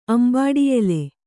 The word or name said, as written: ಅಂಬಾಡಿಯೆಲೆ